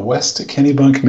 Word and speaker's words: West Kennebunk: I'm calling from West Kennebunk, Maine.